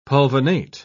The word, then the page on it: pulvinate: British English